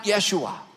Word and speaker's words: Yeshua: That's the transliteration of the Hebrew word that comes from the word Joshua that's pronounced Yeshua.